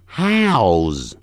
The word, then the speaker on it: house: 房子不要念成 house，你又变成澳门的澳 house，所以念成现在哈哈的哈 house house，再念一遍，下 down down，如何 how how，房子 house house。